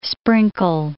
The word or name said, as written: sprinkle